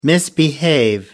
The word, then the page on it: misbehave: American English